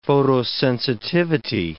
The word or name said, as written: photosensitivity